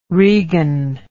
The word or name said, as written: regan